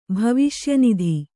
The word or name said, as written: ಭವಿಷ್ಯನಿಧಿ